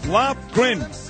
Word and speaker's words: Lofgren: Lofgren. – Lofgren.